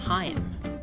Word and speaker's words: Haim: The band whose guitar player is in Licorice Pizza pronounces its name Haim.